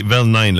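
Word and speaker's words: Vel Nine: Ça s'écrit Velnine, là. V-E-L espace N-I-N-E, là, pour les jeunes non initiés, un peu comme moi, là, mais qui avons bien hâte de découvrir ça.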